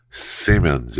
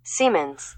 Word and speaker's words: Siemens: Simmons, I think that's how you pronounce that right. I better make sure I pronounce that right. Because I see their buildings all over when traveling, and I always, you know, think to myself, I wonder how you pronounce that exactly. – Siemens. – That's what I thought. That's what I said.